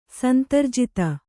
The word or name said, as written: ಸಂತರ್ಜಿತ